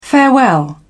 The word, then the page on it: farewell: British English